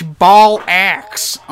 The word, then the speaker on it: bollocks: It's ball axe, okay? Ball axe.